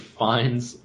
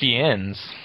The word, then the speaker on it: Fiennes: Fiennes. – You don't pronounce the S? – I don't think so. – Okay. – I think it's Fiennes. – I don't think so. I think it's Ralph Fiennes.